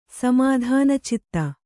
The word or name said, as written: ಸಮಾಧಾನಚಿತ್ತ